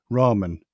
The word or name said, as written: ramen